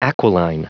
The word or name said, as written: aquiline